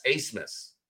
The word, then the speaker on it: Abmas: But who the hell pronounces A-B-M-A-S, Acemus? But apparently the Germans do, and that's how he pronounces it.